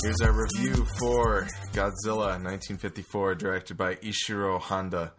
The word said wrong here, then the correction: Honda: Honda, I should say. I know Japanese, I should say it properly.